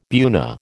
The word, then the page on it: buna: American English